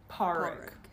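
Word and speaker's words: Pádraic: Parig. Parig. – Yeah, D is silent, so Parig.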